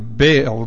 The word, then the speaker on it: Baal: But actually, that's the Hebrew way of pronouncing Baal, B-A-A-L. There's actually an apostrophe in between the A's, and it's Baal, Baal. So if you think I'm stuttering, it's not.